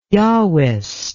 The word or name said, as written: Yahwist